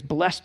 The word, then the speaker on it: blessed: What the heck does blessed mean even? Or in King James, blessed. You know, you got to pronounce that E-D on the end.